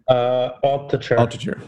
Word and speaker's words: Altucher: Altucher, I'm pronouncing that correctly, right? – Altucher.